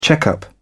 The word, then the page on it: check-up: British English